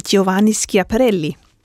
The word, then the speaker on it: Giovanni Schiaparelli: Og samtidig med det, så var der en, øh, en italiensk øh, astronom, øh, Giovanni Schiaparelli.